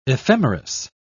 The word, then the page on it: ephemeris: British English